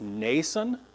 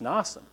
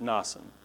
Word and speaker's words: Mnason: This is a super common name. Every time the name shows up, it's Nason. It's an A sound in there. It's Nason. But the Greek here is definitely not. It's an A. It's Mnason.